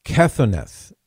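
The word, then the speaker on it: Kethoneth (כתנת): Kethoneth is the way you would pronounce it.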